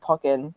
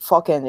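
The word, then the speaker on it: Pokkén: and I had mispronounced Pokken and said Focken instead, and I had to look it up because obviously Focken, Pokken, like the possibilities for video content endless.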